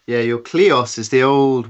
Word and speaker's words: kleos: You talk about the concept of kleos. Am I pronouncing that correctly? The old Greek idea? – Yeah, sounds good to me.